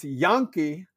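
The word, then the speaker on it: Janke: J-A-X-O-N, and Jaden, J-A-D-O-N, Janky, who they try to pronounce Yankee.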